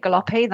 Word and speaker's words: Galoppi: Hi, yeah, my name is Kate Galoppi. That's the pronunciation. It's an Italian surname, to let listeners know.